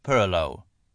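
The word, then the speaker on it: purloo: She even imparted to Lucy, with great ceremony, her secret recipe for crab purloe, a low country dish pronounced with a Huguenot inflection as purloe.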